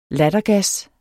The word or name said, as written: lattergas